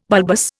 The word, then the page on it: bulbous: Indian